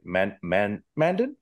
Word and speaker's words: Mandan: Also, Robert Mandan is, I'm not sure if I'm pronounced that right. Mandan? Is that good? – Yeah.